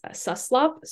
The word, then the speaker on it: Suslop: I mean, people pronounce it differently, but I've been working there long enough to confidently say that it's Suslop.